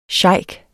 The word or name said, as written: sheik